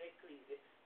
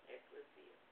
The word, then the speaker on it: ecclesia: I keep saying ecclesia, but it's accurately pronounced ecclesia.